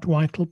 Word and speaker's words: vital: Vital instead of not vital, but vital, hard V, and not vital, which it would be in Slovak.